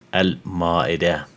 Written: Al-Ma'idah

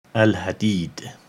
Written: Al-Hadid

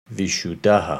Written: Vishudaha

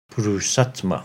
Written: Purusattma

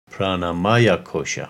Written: Pranamaya Kosha

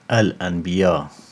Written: Al-Anbiya

